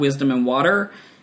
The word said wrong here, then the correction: Warter: It's Wisdom and Warter. W-A-R-T-E-R.